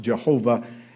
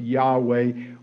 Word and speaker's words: Yahweh: That's sometimes pronounced Jehovah. And scholars have decided it should be pronounced Yahweh.